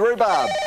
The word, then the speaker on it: rhubarb: Which consonant is silent in the word rhubarb? Billy. – H.